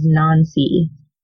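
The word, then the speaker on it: Nainsi: It's pronounced in the audiobooks as Nancy.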